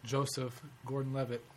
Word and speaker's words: Joseph Gordon-Levitt: I just love Joseph Gordon-Levitt. So you said it right that time. – Yeah. – Well, so, I just love Joseph Gordon-Levitt.